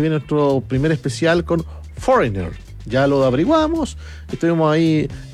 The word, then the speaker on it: Foreigner: Yo puse cómo se pronuncia Foreigner. Y yo escuché y era Foreigner, no Foreigner. Foreigner.